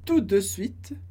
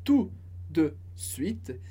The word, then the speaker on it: tout de suite: Tout de suite. Expressão que a gente pode pensar como um só bloco, sem pronunciar o T do final da primeira palavra. Então, tout de suite.